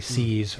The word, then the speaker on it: See's: Right, so I think I'll just touch on the one example, the Warren Buffett example, because he's owned this candy company, See's. You know, I'm pronouncing it, See's spelled S-E-E apostrophe S.